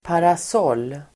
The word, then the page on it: parasoll: paras'ål: